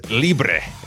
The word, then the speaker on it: Libre: I pronounce it as Libre, Libre, like in Spanish.